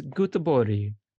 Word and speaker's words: Göteborg: Now, look at you saying Gothenburg, but it's Göteborg in Swedish. – Göteborg. – You're not even pronouncing it.